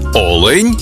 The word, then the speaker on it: олень: в однині і в множині наголос падає на перший склад: олень та олені.